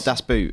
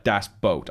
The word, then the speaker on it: Das Boot: It's not Das Boot. – Okay. So, but think of this. Boat, when you pronounce boot, like das boat, and you're 95% there, which is good enough for me.